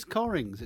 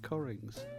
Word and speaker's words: Cockerings: But it's spelt C-O-C-K-E-R-I-N-G-S. So it looks like Cockerings, but it's Corrings. It's pronounced Corrings, you see.